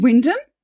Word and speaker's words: Wymondham: So that was a practice in a town called Wyndham. Or you spell it like Wymondham, which is, we were talking earlier about their pronunciation.